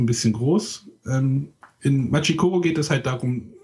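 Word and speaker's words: Machi Koro: Also Machikoro. Japaner neigen dazu, das, die zweite Silbe zu betonen. – Machikoro? – Ja, und jetzt noch zusammenfassen. Machikoro.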